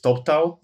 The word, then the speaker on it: Toptal: How do you pronounce the name? – I pronounce it incorrectly because I don't speak English very well, but I call it Toptal. Okay. And my native English speaker colleagues are probably pronouncing it somewhat differently, but it's Top Talent, shortened.